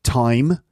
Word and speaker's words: thyme: Thyme is another herb. So, but thyme, the herb, is spelt T-H-Y-M-E, which sounds exactly like time, you know, the thing that is just keeps happening.